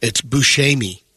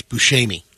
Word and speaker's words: Buscemi: No, it's Buscemi. – I've always said Buscemi. – That's what I've always said. – The proper Italian pronunciation is Buscemi.